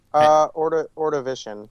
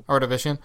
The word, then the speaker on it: Ordovician: Is that how you pronounce it? – Ordovician. – Ordovician.